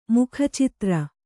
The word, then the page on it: ಮೂಕಿಚಿತ್ರ: Kannada